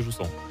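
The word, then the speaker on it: Jusant: I ona to jest francuskie studio, więc zakładam, że wymawia się Jusą.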